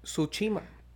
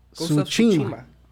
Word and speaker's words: Tsushima: Suchima. – Chusi, ¿qué? Tsushima. – Tsushima.